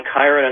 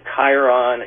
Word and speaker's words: Chiron: Palmistry and the fact that Chiron, it's often, it sounds like, I mean, it's spelled, the way we spell it is C-H, but it really has a K sound, and its etymology really goes back to Latin and Greek, meaning the hand.